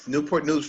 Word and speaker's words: Newport News: And you pastor Calvary Chapel Newport News in Virginia. Did I say that right? – Yes. Newport News, Virginia.